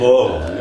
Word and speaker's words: Louisville: And you said that right, man. – I practiced. – It's Louisville, you're right.